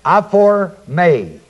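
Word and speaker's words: aphorme (ἀφορμή): It is the Greek word aphorme. A-P-H-O-R-M-E, long E. Comes from apo, A-P-O, meaning away from. And the word hormao, H-O-R-M-A, long O. Aforme means a base of operation.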